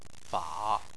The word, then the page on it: fa: 3